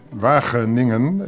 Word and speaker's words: Wageningen: Wageningen. It's Wageningen. Very difficult for you to pronounce.